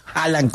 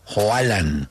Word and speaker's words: Haaland: El pelado, que cuando se refieran a Haaland, no lo pronuncien Haaland como con J, sino Haaland, que es la forma correcta.